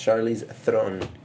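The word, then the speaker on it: Charlize Theron: Actually, that's actually wrong because it's pronounced Charlie's Throne.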